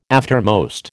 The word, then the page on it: aftermost: American English